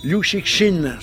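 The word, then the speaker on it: Liu Cixin: Liu Xixin, c'est la bonne prononciation, monsieur Rivera.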